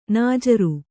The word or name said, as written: ನಾಜರು